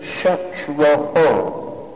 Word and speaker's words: Shatraho: But they mispronounced it. They said, Indra, Shatro, instead of Shatraho. So it changed the meaning. Instead of the enemy of Indra, it now means he who Indra is the enemy of. In other words, he who Indra can kill. One syllable mispronounced.